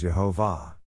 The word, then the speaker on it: Jehovah: Over 2,000 different manuscripts show God's name as being pronounced as Jehovah. The ancient rabbis say this is the way God's name is pronounced, and so do the early church fathers.